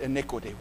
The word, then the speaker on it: iniquity: The significance of that word becomes very clear if we'll just let the emphasis fall on the first syllable. Now look at the first syllable of the word iniquity.